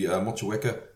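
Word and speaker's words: Motueka: I'm still not pronouncing Matuka right. Matuika. – So in Kiwi terms, it would be Mochiweka, Waiti, and Ruaka.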